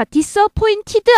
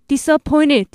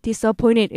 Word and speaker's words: disappointed: 자, disappointed 아니고요. 트 발음 약화돼서 disappointed, disappointed 이렇게 됩니다.